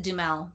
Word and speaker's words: Duhamel: Yes, I pronounce it Dumel, Josh Dumel.